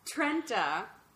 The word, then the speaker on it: Trenta: I am drinking a Trenta. – Trenta. – Trenta. Yes. Learned how to pronounce that earlier. Trenta, very berry hibiscus refresher with lemonade.